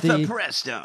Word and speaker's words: mifepristone: And they took the Mifepristone or whatever, how the fuck you pronounce that? – Mifepristone.